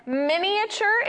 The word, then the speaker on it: miniature: So, according to the internet, miniature is the correct way to pronounce it. You pronounce the E and the A, miniature.